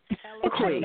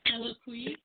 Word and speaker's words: Eloquii: The name of it is, I don't know how to pronounce it properly. – Eloquii. – That for a fact. Eloquii. – Eloquii. – Eloquii. So you do know about it.